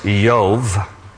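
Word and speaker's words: Iyov: In Hebrew, his name is pronounced Yov. We would say Job.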